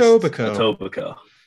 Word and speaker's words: Etobicoke: Etobicoke.